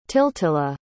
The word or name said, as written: tiltilla